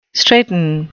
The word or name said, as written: straiten